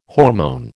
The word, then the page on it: hormone: American English